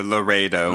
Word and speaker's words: Laredo: the Laredo. Is that how you say it? – The Laredo. – Laredo, yeah. Which is something that Rickenbacker did in 2015,